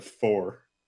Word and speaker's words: Pfhor: So in Marathon, there were enemy races, and one of the enemy races was the four, the P-H, or the P-F-H-O-R. – That's how it's pronounced, the four? – Yeah, the four.